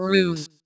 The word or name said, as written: nun